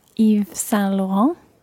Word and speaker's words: Yves Saint Laurent: And it's spelled Yves Saint-Laurent. Who is this designer and which shop is it? – The designer is Yves Saint Laurent.